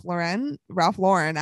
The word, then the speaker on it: Lauren: And it's done by Ralph Lauren, actually. I've heard that that's actually how you pronounce it. So we were wrong the first time.